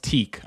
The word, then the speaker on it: Tique: I believe it's pronounced teak. I could be wrong with that. It's TIQUE.